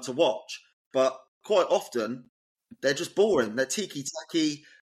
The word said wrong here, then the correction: tiki-taka: tiki-taka. I keep saying that wrong.